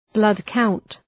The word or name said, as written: blood count